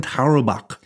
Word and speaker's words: Tarwebok: It's spelled T-A-R-W-E-B-O-K, and it's pronounced Heineken Taro Bock.